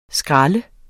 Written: skralde

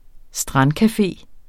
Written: strandcafe

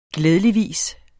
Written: glædeligvis

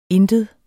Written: intet

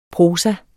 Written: Prosaen

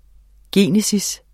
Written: genese

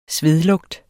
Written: svedlugt

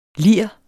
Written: lirens

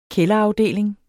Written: kælderafdeling

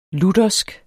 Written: luthersk

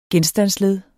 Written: genstandsled

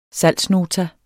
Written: salgsnota

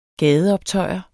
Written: gadeoptøjer

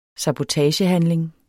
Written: sabotagehandling

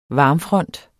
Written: varmfront

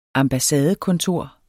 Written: ambassadekontor

